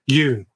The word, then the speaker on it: Yeun: Yeun, sorry, that's how you pronounce it.